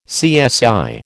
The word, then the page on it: CSI: American English